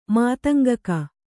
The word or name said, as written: ಮಾತಂಗಕ